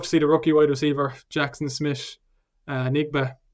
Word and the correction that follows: Njigba: Not sure how to pronounce that Njigba. – Right. Njigba.